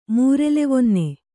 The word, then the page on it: ಮೂರೆಲೆವೊನ್ನೆ: Kannada